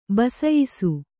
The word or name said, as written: ಬಸಯಿಸು